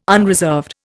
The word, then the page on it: unreserved: Indian